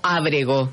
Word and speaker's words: Abregó: ¿Abregó o Abregó? – Abregó. – Abregó. Con acento en la razón.